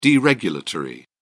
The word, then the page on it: deregulatory: British English